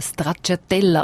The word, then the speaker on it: Stracciatella: Der Link bei uns auf der Seite deres1.ch, genauso Rosetta Lobardo, wo mit ihrem Programm Stracciatella unterwegs ist. Habe ich es richtig gesagt? – Ja, genau. – Kann man auch schauen, wenn und wo.